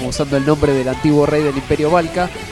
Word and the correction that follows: Belka: Belka, bien la corrección. Encima lo escribí bien, lo dije mal.